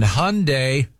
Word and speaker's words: Hyundai: Right now, I want to talk to you about things that rhyme, like Sunday and Hyundai. – And you know what else rhymes with Hyundai? – Fun day and Fry-yay.